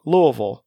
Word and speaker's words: Louisville: But I pronounce it correctly because I live here, and people who don't live here say it wrong. Louisville. – Louisville. – Yeah, you don't say half the words, half the letters that are on pages. You just skip over them.